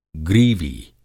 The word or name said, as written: ग्रीवी